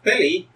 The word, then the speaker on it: Tilly: So Priya's like, well, Tilly, why don't you, am I pronouncing it correct, she says? – It's Tilly, but you can call me Tel.